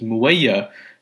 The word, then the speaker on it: Muawiya: I believe his name is pronounced as Muawiya.